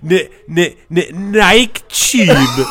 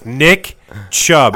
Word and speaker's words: Nick Chubb: You're like, Nike Chubb. And like, everyone knows what you're going to do. And then you still do it. And you still laugh. – You know what's funny? I didn't do it on purpose this time. – Yeah, fucking right. You mispronounced Nick Chubb. – I said two syllables. – I said Nick. – But you didn't say Chubb. – I said tube.